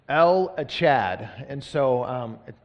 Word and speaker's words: El Echad: Um, but as I talk about El Echad, I'm going to say it like that because that's the way that it was intended to be said. And so, like I would say Yahweh, like that, I would say El Echad.